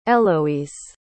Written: héloïse

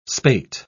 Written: spate

